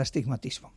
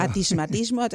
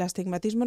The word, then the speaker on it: astigmatismo: ¿Qué es eso del astigmatismo? – Sí, el astigmatismo, que además es que les cuesta mucho. Claro. Atismatismo, no saben muy bien muchas veces cómo pronunciarlo correctamente.